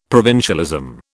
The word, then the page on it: provincialism: British English